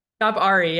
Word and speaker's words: ShopARI: I've been saying this right, ShopARI, right? – ShopARI, yeah, because Ari is the avatar.